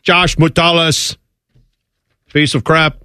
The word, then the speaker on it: Metellus: Am I saying that right? – Levert Hill. – Please give me. – Levert Hill and Josh Metellus. – Josh Metellus and Levert Hill. – Not even close.